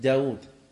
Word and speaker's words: داود: يكتب ولا ينطق، وفي شيء ينطق أيضا ولا يكتب، داود، واو،